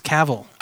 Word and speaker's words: Cavill: I thought it was Cavalier. – Cavall. I thought it was Cavill. – Cavill? Yeah, I think it's Cavill. Yeah, okay. – It's pronounced Henry. – Oh, word. – That's H-E-N-R-Y and then Cavill.